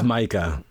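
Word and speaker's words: Maika: Is it Micah? – It's Micah.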